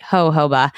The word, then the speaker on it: jojoba: And then one of my favorites, which is actually a serum, is the jojoba. Did I say that right? Jojoba. Thank you. That's how it is.